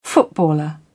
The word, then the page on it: footballer: British English